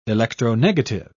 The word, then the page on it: electronegative: British English